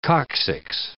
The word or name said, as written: coccyx